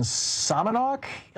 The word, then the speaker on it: Sammamish: Picking up your calls here, Patricia in Sammamish, Illinois. Am I saying that right, Patricia? – You are.